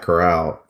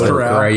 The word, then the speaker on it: Corral: The only quarterback the only quarterback that I like in this draft is Matt Corral from Ole Miss. I always mispronounce how you say his last name. I think it's Corral. – Yeah, I think it's Corral. Okay.